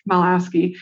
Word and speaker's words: Michalowicz: I first heard of this concept a few years ago when I heard at an event, when I heard the author, Mike, I can never say his name right, Michalowski, I think, Michalowicz, speak at an event.